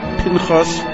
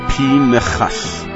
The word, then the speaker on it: Pinchas: The name is Pinchas. Pinchas. The shva under the nun is a shva na. It belongs to the second syllable of the name, not to the first part.